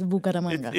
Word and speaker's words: Bucaramanga: Bucaramanga. – Yes, Bucaramanga.